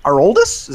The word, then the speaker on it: Aroldis: Aroldis? Is that how you pronounce that? – Yeah.